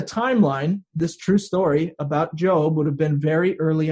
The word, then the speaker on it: Job: His name looks like Job, but is pronounced Job. They apparently just forgot the bossy E that goes on the end, right, teachers? But Job is the name of a man who lived very early on in the Bible.